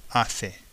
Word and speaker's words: hace: Hace. H-A-C-E.